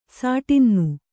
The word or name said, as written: ಷಡಾನನ